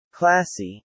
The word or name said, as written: classy